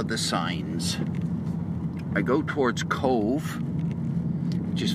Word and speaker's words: Cobh: For those of you who don't know, it's spelled C-O-B-H, because B-H in Ireland is pronounced as a V, or B-H in Irish is pronounced as a V, as in Siobhan.